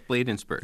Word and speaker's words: Bladensburg: Yes, Bladensburg.